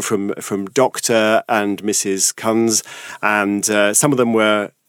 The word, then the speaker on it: Kunz: 1974, a sociologist called Phil Kuntz Not sure how to pronounce the name. – On the radio. Kunz. – Yes. – How would you say that? Anyway, K-U-N-Z. Philip Kunz, shall we say.